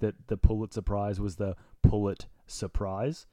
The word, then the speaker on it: Pulitzer: We're going through Kendrick Lamar, like trying to find, in honor of his Pulitzer Prize. – Yeah. – But I think he said Pulitzer Prize as well. – That's how you pronounce it. You pronounce it Pulitzer.